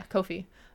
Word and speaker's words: Ko-fi: So that'll be up on my Ko-fi. I think it's pronounced Ko-fi. Ko-fi? Yeah, Ko-fi. So, yeah, if you like cosplay stuff, you want to follow along.